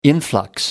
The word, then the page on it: influx: American English